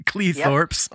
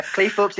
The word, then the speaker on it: Cleethorpes: Matt from Cleethorpes, United Kingdom. Am I saying that correctly? Do you know? – Mm-hmm. – Cleethorpes, yep. In United Kingdom. – Yeah, Cleethorpes is right.